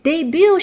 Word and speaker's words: debut: Debut 是什么意思？首先，这个词的拼写有一点和发音不太一样，debut，但它的拼写是 d e b u t，末尾的 t 是不发音的，d e b u t，读音是 debut。